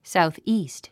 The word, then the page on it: southeast: sàuθ íːst; サウスイースト